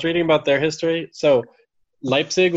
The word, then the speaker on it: Leipzig: Leipzig, yeah.